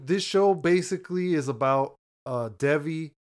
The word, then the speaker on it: Devi: But her name on the show is, like, I don't know if I'm saying it right either, but it's D-E-V-I, like Devi, Devi. – Yeah, Devi.